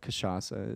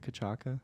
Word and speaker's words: cachaça: I call it cachaça. Is it cachaca? – No, that's a soft C.